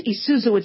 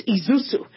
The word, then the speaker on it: Isuzu: It was my, it's not pronounced Isuzu, it's Isuzu.